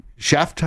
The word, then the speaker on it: Scheft: John Sophie Scheft, I believe. Did we say that right? – Yes.